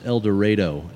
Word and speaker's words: El Dorado: So we are here in El Dorado, Arkansas, and now I want to first clarify that the pronunciation of that, I got it wrong the first time, so I'm going to make sure that for the rest of the time, it's El Dorado, and I'll get it right. – Correct. That's how it is pronounced down here.